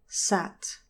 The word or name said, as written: sat